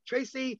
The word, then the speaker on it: Tracy: Tracy, T-R-A-C-Y, Lamori, L-A-M-O-U-R-I-E.